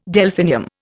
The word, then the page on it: Delphinium: Indian